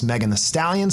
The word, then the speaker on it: Megan Thee Stallion: A rising star along with her is in this song is Megan Thee Stallion, spelled the but pronounced the.